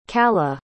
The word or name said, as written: caladh